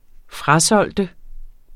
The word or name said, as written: FRASOLGT